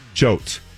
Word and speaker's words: Choate: And I was supposed to say it like Oates. Coates. Oates. Choate. And it sounds like I still demolished it.